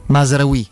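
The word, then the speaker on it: Mazraoui: Oh, eh, Piero, che mi dici di Masurai Masraoui, anzi, pronunciamolo bene.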